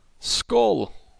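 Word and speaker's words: Skål: How do I say that? S-K-A-L. – Skull.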